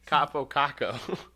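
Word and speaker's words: Kaapo Kakko: How do you say Kapu Kakao? Kapo Kako.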